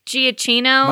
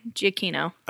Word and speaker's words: Giacchino: I always said Giacchino. – Michael Giacchino. – Growing Giacchino. – Up and stuff. But I think, yeah, I think it's like Pinocchio Giacchino. – Okay. That sounds right.